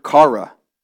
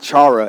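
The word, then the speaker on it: kara (χαρά): The first joy is this word, kara. Can everybody say kara? Kara. This is how you say it in Greek. It's not chara, it's kara.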